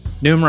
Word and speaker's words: Noom: Noom rhymes with boom. – Boom. – N-O-O-M.